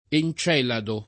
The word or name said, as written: Encelado